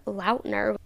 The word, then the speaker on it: Lautner: Taylor Lautner, or Lautner. That's apparently how his name is technically pronounced, but I also tagged Presley Cash, and Presley Cash liked my reel, which was very fun.